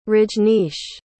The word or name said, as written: Rajnish